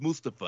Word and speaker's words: Mustafa: That's how the man pronounces his name, sir. It's Mustafa.